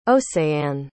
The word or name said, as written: océane